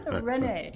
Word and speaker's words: Rene: Say my name. Rene.